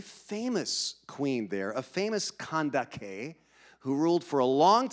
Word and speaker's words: Kandake: And by the way, it was not pronounced like we pronounce it in English, Candace. It was pronounced Kandake.